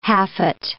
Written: haffet

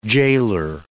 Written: gaoler

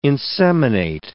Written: inseminate

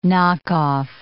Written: knockoff

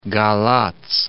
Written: Galati

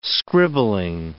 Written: scribbling

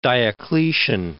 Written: Diocletian

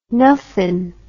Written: nuffin

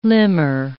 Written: limmer

